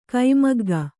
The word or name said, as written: ಕೈಮಗ್ಗ